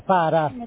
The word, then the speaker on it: pare: També la E àtona se pronuncia A. – Sí. – Pare se pronuncia para. – Para.